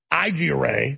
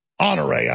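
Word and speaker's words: Honoré: So I'm going to play for you when we come back, General Igiere. Am I pronouncing that right this time? Honore.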